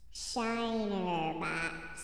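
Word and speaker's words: Shiner Bock: Shinerbach. Is that it? – That's it. Yeah.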